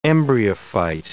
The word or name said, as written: embryophyte